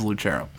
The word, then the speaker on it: Lucero: I go by my my actual pronunciation of my name is Lucero. – And Everybody gets it wrong. You have an accent as well. – Yeah, it's it's Italian.